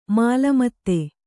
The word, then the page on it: ಮೇಲ್ಮಿತಿ: mēlmiti